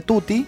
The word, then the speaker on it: Tuti: Y Tuti Blanc en YouTube lo pueden buscar como T-U-T-I, así como suena Tuti.